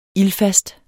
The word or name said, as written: ILDFAST